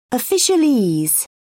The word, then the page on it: officialese: British English